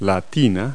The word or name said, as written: bathtub